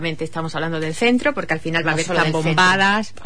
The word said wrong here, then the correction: zambombas: Uy, lo he dicho mal. Zambombas. Aquí hay que decir, no tan fino.